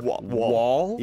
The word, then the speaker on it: Woll: Wall? Yeah. It's amazing. Even though it's spelled W-O-L-L. – It doesn't matter when you say it.